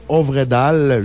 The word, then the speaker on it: Øvredal: Et c'est du producteur André Øvredal.